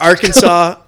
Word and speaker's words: Arkansas: Arkansas, I know the capital's Little Rock. – Yeah. – It's spelled like Kansas, but it's not pronounced Kansas.